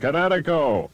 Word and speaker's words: Kinetico: Everybody knows that Kinetico is spelled K-I-N-E-T-I-C-O. – I thought the instructor said Connecticut, on the East Coast. – Son, you should have remembered kinetic as in kinetic energy with an O on the end. Kinetico. – Kinetico. Spell it K-I-N-E-T-I-C-O.